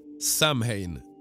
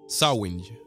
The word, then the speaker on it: Samhain: Som eh, folk som har sett på till exempel halloween-filmerna har säkert stött på det här en gång, men då tror jag det är fel uttalat. Då uttalas det Samhain, men det är egentligen då Sawing.